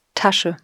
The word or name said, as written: Tasche